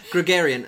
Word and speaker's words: Gregorian: I stop because I don't know how to pronounce it. – Gregorian, the Gregorian... Gregorian calendar. – 好，所以中文是国历。 – 所以我们的 calendar 是 Gregorian. – 对。 – 所以在中文你说什么？What's Gregorian in 中文？ – 国历。